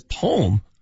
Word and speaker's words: poem: Larry from Aurora says it has two syllables, not one. – No, it doesn't. – This guy says poem almost wrecked my truck.